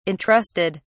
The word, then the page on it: entrusted: British English